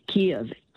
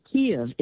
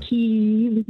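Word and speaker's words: Kyiv: So if you know Stevie Wonder, the songs in the key of life, say it to yourself, songs in the key of life, then take off songs in the key of Kiev. Kiev is how English you should pronounce the capital of Ukraine. It's not Kiev, and it's not Kiev like the Russians.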